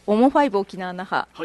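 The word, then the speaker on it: OMO5沖縄那覇: はい、あ、はい、私たちの名前だけでも覚えていただければと思いまして、はい、うん、オモファイブ沖縄那覇、はい、ローマ字で OMO 数字の 5と書い、 5 と書きます。その後漢字で沖縄那覇と書いて、うん、オモファイブ沖縄那覇と読みます。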